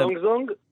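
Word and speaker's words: זונג זונג (中中): איך מבטאים את השמות? אז בוא נגיד את זה. זונג זונג, לראשונה קוראים זונג זונג, לשנייה וואווה, שזה, אם מחברים זונג וואה, זה ביטוי לסינים בסינית.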